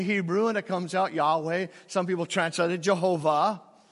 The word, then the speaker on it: Yahweh: Yahweh is the way we pronounce it because we don't know the vowels that go with YHWH in the Hebrew, the tetragrammaton.